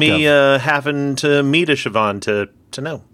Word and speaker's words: Siobhan: S-I-O-B-H-A-N. – Yes, that's it. That's exactly it. It looks like Shibuhan. – Yeah, Siobhan.